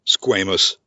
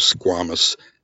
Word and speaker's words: squamous: I, I mispronounced squamous. I always thought it was squamous.